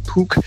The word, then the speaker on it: puck: Mens dem, vi spiller ishockey med, de hedder jo så nok ikke en puck, men en puk, selvom der er også noget U-C-H-K i dem.